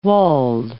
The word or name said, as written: walled